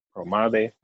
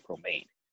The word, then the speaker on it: Promare: You pronounce it Promare, but it looks like Promade.